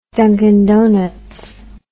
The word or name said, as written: dunkin donuts